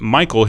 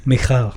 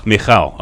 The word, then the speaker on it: Michal: And so they're worshiping, and it says that Michael, his wife, or Michal, how do you pronounce? I want to say Michael because it spelled very close to my name. – Michal. – Michal. Okay, thank you.